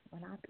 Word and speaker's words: Lenape: And you spell Ramapo, R-A-M-A-P-O-U-G-H, Lenape, L-E-N-A-P-E. Lenape. Ooh, did I just spell that right? – I think so, L-E-N-A-P-E, Lenape.